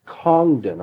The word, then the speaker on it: Congdon: Congdon, I think that's the way he pronounces his name, C-O-N-G-D-O-N, in volume 35 of Bibliotheca Sacra — that will undoubtedly be the new series, I'm sure — volume 35.